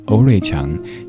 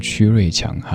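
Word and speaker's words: 区瑞强: 他叫欧瑞强，请千万不要念成曲瑞强哈。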